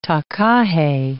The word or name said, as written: takahe